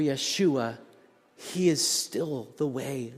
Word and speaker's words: Yeshua: If you were worshiping in a Hebraic setting, you'd be worshiping Yeshua, the Hebraic pronunciation of Jesus,